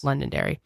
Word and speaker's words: Londonderry: And then at 5, her phone pinged a cell phone tower within 20 miles of Londonderry, New Hampshire. Am I saying that right? – Yes. – Londonderry.